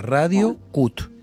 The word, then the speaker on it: Radio Cut: Radio, es una página que se llama, sí, Radio Cat. Y vos podés escuchar este programa, o el que quieras. – ¿Con cada kilo? – No. – C-U-T. – Se escribe C-U-T. Sí. Así termina. Radio Cut.